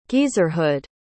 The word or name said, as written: geezerhood